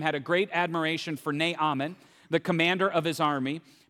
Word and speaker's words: Naaman: I've always called him Naaman. And as I was getting ready, some of my team members gave me a more accurate pronunciation of his name. It's Naaman.